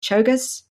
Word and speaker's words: Czolgosz: I never can say it right. Leon Czolgosz? – Czolgosz. – Well, there you go.